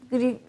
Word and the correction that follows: girift: e, girift doğru telaffuz ettim inşallah evet.